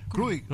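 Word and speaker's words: Jurić: Aquí no nos falta Víctor para la pronunciación exacta, porque es J-U-R-I-C. No sé si Juri o Krurik, ¿cómo se...? – Krurik.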